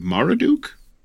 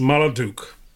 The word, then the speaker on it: Marduk: Wait, would you be in the service of Marduk? – It is pronounced Marduk. But yes. – You said Marmaduke.